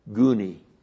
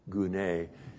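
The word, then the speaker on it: gune (γυνή): There's one Greek word, gouni. You know, that's not the one you want, gouni. It's actually gune, and if I was you, I would pronounce it june, you know.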